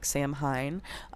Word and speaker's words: Samhain: We're creeping towards the 31st, Samhain, which is spelled like Samhain, but Samhain.